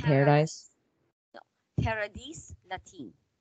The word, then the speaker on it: Paradis Latin: And then after this, he became a waiter at Paradis Latin, Paradis Latin. – Oh, I thought you don't pronounce the S. – I told you, like, all languages, there are exceptions.